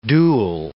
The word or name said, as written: dual